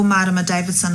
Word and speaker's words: Marama Davidson: Kia ora, ko Marama Davidson aho.